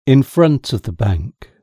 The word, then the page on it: in front of the bank: British English